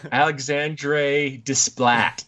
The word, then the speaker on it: Alexandre Desplat: No, it's not Desplat. – You gotta say it like an American. Alexandre Desplat.